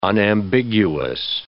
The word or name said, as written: unambiguous